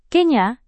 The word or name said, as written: kenya